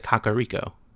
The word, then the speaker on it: Kakariko: Yeah, he pronounces it Kakariko. – Yeah, Kakariko. So I'm going to take that one as the canon pronunciation for now.